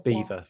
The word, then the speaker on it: Belvoir: It's spelled like Belvoir, but it's Beaver for some reason. Just a thing that posh people say in it.